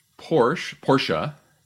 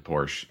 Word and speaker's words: Porsche: All right, this is Porsche, if you want to say it correctly. I know that because I had one and I said Porsche and I was corrected many times.